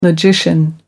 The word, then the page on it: logician: American English